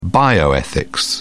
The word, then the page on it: bioethics: British English